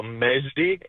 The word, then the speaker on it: Majdi: M-A-J-D-I. So Mejdi. It sounds like it's with an E.